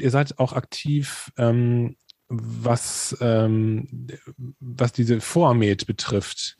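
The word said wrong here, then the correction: FOAMed: Habe ich das richtig ausgesprochen? – Foam, würde ich sagen.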